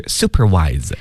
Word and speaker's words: supervise: supervise 那朋友们注意啊，这个里的 V-I-S-E 这个 S 呢它要读成的是 Z 这个音，所以是 supervise。